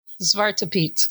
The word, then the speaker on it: Zwarte Piet: Or in Dutch, it's pronounced Zwarte Piet.